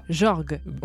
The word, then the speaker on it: Jorge: J-O-R-G-E. – Ah, c'est pas brésilien ça. – Jorge, on va dire Jorge.